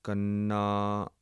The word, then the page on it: kanna: Punjabi